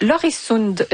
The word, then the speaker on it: Öresund: Je suis mélangée avec le pont de Öresund. J'ai choisi la prononciation danoise, mais j'aurais pu aller du côté de l'accent suédois, Yann.